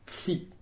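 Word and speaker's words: ci: Une petite parenthèse, on peut, certains espérantistes, mais ils sont vraiment très très peu nombreux, pour tutoyer, emploi tsi. Ça s'écrit C-I.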